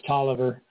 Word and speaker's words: Taliaferro: And Taliaferro, it's actually pronounced Tolliver, spelled Taliaferro. There's a Tolliver County in Georgia. That's why I know that. Spelled the same way.